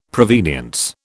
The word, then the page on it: provenience: British English